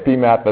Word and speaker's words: PMAT: Right, so if you want to say PMAT, that's fine. It kind of rhymes with HAZMAT, which is what?